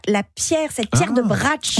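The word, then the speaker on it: Brač: Bratsch.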